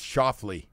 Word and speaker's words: Schauffele: I guess after you butchered his name the whole week, it's Schauffele.